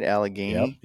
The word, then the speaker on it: Allegheny: Did I say that right? Allegheny? – Yep.